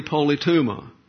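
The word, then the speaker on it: politeuma: Inside the bubble, the advancing believer functions under the standards common to the heavenly polytuma. You behave as a citizen. Now let's look at the word polytuma. Polytuma is the correct pronunciation.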